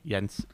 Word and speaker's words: Jens: Jens. It's a soft J, like Yagi.